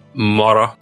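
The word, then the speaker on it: moro: Morrow. – Morrow. What does that mean? – That's a good... You roll your R's well.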